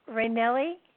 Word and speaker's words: Raynell: Okay, we've got, I hope I pronounced this right, Raynelli? Raynelli? – Raynell. That was good, though. – Raynell. – Yeah. – Oh, the last one is silent.